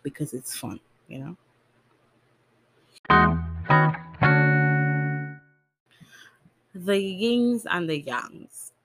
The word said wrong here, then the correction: yin and yang: Yin and yang.